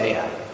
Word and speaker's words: Ai: I looked up Ai to make sure I pronounced that correctly.